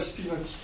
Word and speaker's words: Spivak: Je sais que c'est Spirac. – Spivak. Hein? Gayatri Spivak.